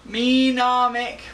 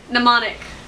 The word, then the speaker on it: Mnemonic: Menomic. What? – Mnemonic.